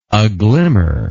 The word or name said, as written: aglimmer